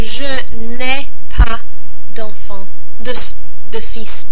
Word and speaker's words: fils: Fils. – So F-I-L-S. – F-I-L-S, yeah.